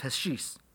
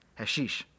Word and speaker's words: hashish: This was induced by an oral dose of hashish. Wow, I said that wrong. Hashish, thank you.